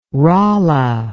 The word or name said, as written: rolla